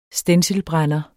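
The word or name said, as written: stencilbrænder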